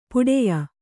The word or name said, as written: ಪಟ್ಟೆಯ